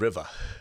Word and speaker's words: Rivah: And yes, it is River. – River. – River. – River. – Because it's spelled R-I-V-A-H. River.